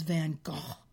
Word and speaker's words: Van Gogh: Of course, everyone, we pronounce it, Americans pronounce it Van Gogh. It really is Van Gogh, cough more spitting.